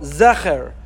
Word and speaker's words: זכר: So the Vilna Gaon, the Gra, has it with a Segol, Zecher.